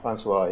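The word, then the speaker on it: François: There was an interview earlier this week published in Kotaku with Ubisoft VP of Editorial, Tommy Francois, is that how you say it? – Francois, yeah.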